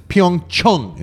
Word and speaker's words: Pyeongchang: The name of Pyeongchang, which is holding the 2018 Winter Olympics, is Pyeongchang. Mm-hmm. Pyeongchang is how you pronounce it.